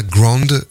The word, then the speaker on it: Grond: Et puis à l'instant, Fiona Grond, ça s'écrit G-R-O-N-D, donc je disais, une vocaliste qui s'associe à des musiciens de jazz, Moritz Stahl et Philippe Schiepek.